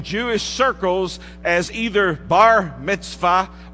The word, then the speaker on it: mitzvah: In the Hebrew, it's pronounced mitzvah.